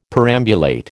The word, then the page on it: perambulate: American English